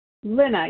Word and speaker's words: Lynna: Lena. – Tricia D. – What was that last lady? Was it Minna? – Lena, yes. – Could you spell it, please? – L-Y-N-N-A.